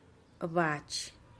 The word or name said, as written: abatxih